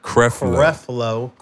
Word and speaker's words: Creflo: Creflo.